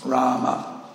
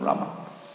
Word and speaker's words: Rama: It's, it's, a, it's a short A in Rama, not Rama, but Rama.